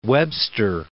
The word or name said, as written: Webster Groves